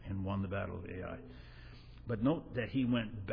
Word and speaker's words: Ai: between Bethel and Ai." This is pronounced Ai, but it's got an H in front of it.